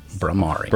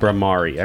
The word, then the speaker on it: Bhramari: It's Bhramari. That's how I'm going to pronounce it. Bhramari. – Bhramari. I believe that's right.